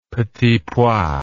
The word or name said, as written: petits pois